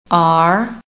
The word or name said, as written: ARE